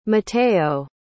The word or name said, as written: mateo